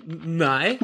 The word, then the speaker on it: Nny: In dem Comic geht es halt um Johnny C., auch Nui oder so ähnlich genannt. Ich habe keine Ahnung, wie man das aussprechen soll. N-N-Y. Okay. – N-N-Y. Nui.